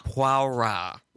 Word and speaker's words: Poirot: But before we do, let's talk a little bit about one of your main detectives, Agatha Christie, Inspector Poirot, I think is the way you pronounce his name, correct? – Poirot. Yes. – That's the correct pronunciation.